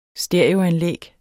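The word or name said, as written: STEREOANLÆG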